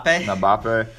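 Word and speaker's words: Mbappé: How do you say his last name? – What are you trying to say? – Mbappe or something like that. – Mbappe.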